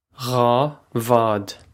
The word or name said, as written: dhá bhád